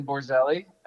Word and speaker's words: Borzelli: My name is Brandon Borzelli, and I live in Lebanon, New Jersey. And ooh. – It's pronounced apparently several different ways, but that's how I pronounce it.